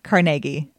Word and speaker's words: Carnegie: Pittsburghers say Carnegie. Yeah. We put in the extra syllable, um, and so Russo, who was his friend, had actually been in the army for two years.